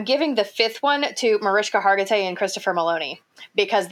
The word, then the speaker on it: Mariska: It's Mariska. – There's a silent H in there.